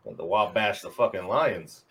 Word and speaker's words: Wabash: Wabash? Am I saying that right, Cherie? – No, she's saying no. – How do we say it, Cherie? – Wabash. You said it Wabash. Right Monday night.